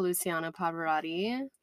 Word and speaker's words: Luciano Pavarotti: And we listened to Luciano Pavarotti.